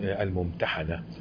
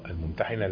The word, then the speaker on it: الممتحنة: خلي بالكم هو اسم الممتحنة، البعض بينطقها خطأ الممتحنة، لا خطأ الممتحنة، مش هي اللي بتمتحن، لا هي اللي بتمتحن، هو كده.